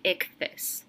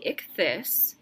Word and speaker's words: Ichthys: In my research of Pisces imagery, I also encountered something called the Vesica Pisces and the Icthys. That's spelled I-C-H-T-H-Y-S.